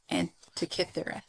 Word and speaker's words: Antikythera: How do I say it? Antikythera. – Yep. – Antikythera? – Uh-huh.